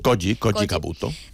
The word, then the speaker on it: Koji Kabuto: Però el creador de Mazinger va trencar tots els motlles en clavar a Koji, com es pronuncia? – Koji Kabuto.